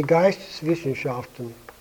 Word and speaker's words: Geisteswissenschaften: In German, I think it's much more expressive, it's the Geisteswissenschaften. That's a pretty long word, but I'll spell it anyway. Capital G-E-I-S-T-E-S-W-I-S-S-E-N-S-C-H-A-F-T-E-N.